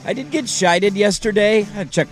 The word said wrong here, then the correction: chided: You mean chided? – Chided?